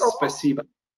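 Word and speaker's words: спасибо: In Ukrainian, again, the native tongue where I was born, it was Russian, which is spasiba. – Oh, spasiba.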